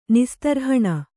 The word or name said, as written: ನಿಸ್ತರ್ಹಣ